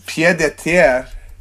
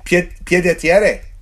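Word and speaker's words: pied-à-terre: It's pied de terre. Pied de terre. I turned it into an Italian word.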